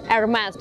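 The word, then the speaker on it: Hermès: Так вот правильно, ребята, произносить Hermes. Cherry Hermes. Hermes, так звать основателя этого бренда. Французы, кстати, произносят это название бренда без последнего звука S и делать с точки зрения французской фонетики абсолютно правильно. Первая буква не немая, ударение на последний слог, и последняя буква не произносится.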